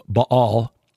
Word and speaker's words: Baal: My talk at Morningside Church last week dealt with Elijah's confrontation with the priests of Baal. Is the correct pronunciation, just for you scholars out there.